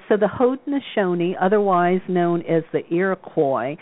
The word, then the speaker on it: Haudenosaunee: is it Haudenosaunee? Is that it? – The Onondaga pronunciation, as well as I can do it, is Haudenosaunee. But I have to tell you, it took me about 2 years for that to come trippingly to my tongue.